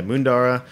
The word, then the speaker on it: Moondarra: We pour a sparkling rosé by the glass from a producer named Mundara. They're in Victoria. – Spell it for me, because we post. – M-O-O-N-D-A-R-R-A. – Okay, I'll double check it. – Yeah, Mundara. It's a biodynamic grower.